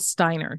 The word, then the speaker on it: Steiner: Today's guest is Michelle Steiner.